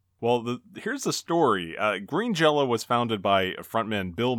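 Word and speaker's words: Green Jellÿ: but the Y had a little umlaut over it. And they claimed that that made the Y sound like a long O. So even though written out it was Green Jelly, they still pronounced their name Green Jello.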